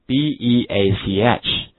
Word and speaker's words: beach: 沙滩这个词，beach，跟我读一下，beach。好，这就是我们的沙滩，一定要读成长音，好，不能读短了。